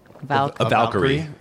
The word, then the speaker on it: Valkyrie: Now my question was, and I can never, I mean, how do you pronounce it? Is it Valkyrie? – Valkyrie. Yeah, Valkyrie.